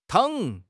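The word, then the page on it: tang3: tone 3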